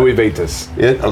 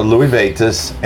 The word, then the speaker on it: Louis Vuitton: Louis Vuitton. – Louis Vuitton, and that I really liked.